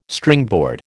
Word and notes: Stringboard: American English

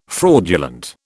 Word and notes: fraudulent: British English

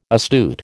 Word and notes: astute: American English

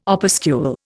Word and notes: opuscule: Indian

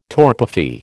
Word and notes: torpefy: American English

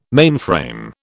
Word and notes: mainframe: British English

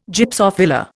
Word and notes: Gypsophila: Indian

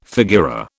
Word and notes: figura: British English

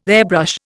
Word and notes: airbrush: Indian

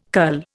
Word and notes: cull: Indian

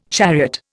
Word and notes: chariot: Indian

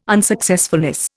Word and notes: unsuccessfulness: Indian

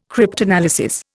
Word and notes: cryptanalysis: Indian

